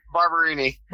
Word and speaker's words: Barberini: So how's it pronounced? – Barberini.